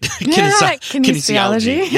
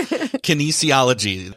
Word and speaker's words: kinesiology: Kinesiology. – Yes. Kinesiology.